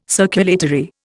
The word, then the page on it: circulatory: Indian